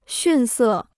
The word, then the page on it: 逊色: xùn sè